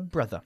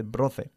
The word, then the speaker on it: brother: Una palabra que a veces pronuncian mal los españoles es brother. No se dice brother. Brother. – Brother. – Bajando una vez más la mandíbula, mm-hmm, para producir este sonido.